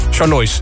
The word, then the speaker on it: Charlois: En de A15 van Ridderkerk naar Oostvoorne, ook een kwartier vertraging over 3 kilometer tussen Charlois, zeg ik het goed? Charlois. Ik vind dat altijd moeilijk. Is het Charlois? – Charlois. – Charlois. – Rotterdam-Charlois.